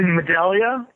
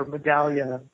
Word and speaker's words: Medaglia: It's actually Medaglia, but okay. – It is a close second, and I'm perfectly comfortable with that. That is the American pronunciation of my name. – Oh, okay. – It's an Italian name, and so Medaglia is how you would say it in Italy. And it actually means medallion.